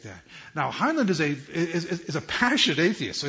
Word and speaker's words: Heinlein: It's a science fiction author, Robert Heinlein. Am I pronouncing it right? Who said that? No, Heinlein. No, H-I-E-L-A-I-N.